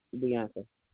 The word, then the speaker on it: Bianca: Bianca. – Spell that for me. – B-I-A-N-C-A. – B-I-A-N-C-A? – Correct.